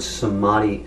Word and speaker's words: samadhi: So what does the word samadhi actually mean, and how does it reveal Krishna? – Samadhi. The Sanskrit word samadhi is this prefix sam. And then the word adi with a long A, some adi.